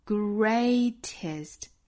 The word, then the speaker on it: greatest: Greatest 这个单词，我们注意它第一个元音呢是双元音的 a 的发音，那么第二个元音呢是短音 a greatest，最后的这个音不要发成紧张的 e 音，应该是 a greatest。